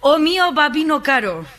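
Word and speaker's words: O mio babbino caro: A ver, Isaías, se lo digo bien porque me he ido a la UBI por decir esto mal la semana pasada. O mío, babino caro.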